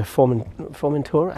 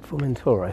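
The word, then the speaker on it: Formentera: They've sailed from Estepona off to Formentura. I can't say that right. Formentura, I think.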